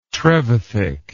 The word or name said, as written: trevithick